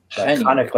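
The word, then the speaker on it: Janín: And then Michael Janin, is that how you pronounce his name, right? – Janin. Chanik, like Chanukah.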